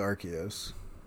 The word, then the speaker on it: Arceus: Or Arceus, I believe, is the canon pronunciation. But what? – Pretty sure, yeah. Like, in the movie and everything, it's Arceus.